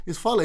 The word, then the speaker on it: Fale: And then I got to the point where, you know, I'd say my name and say, oh, your name's Phil. And I'm like... My name's not Phil. My name is Fale. And then even if I did say Fale, they'd say Fail.